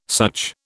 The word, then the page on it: such: British English